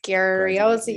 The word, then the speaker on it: Graziosi: Graziosi.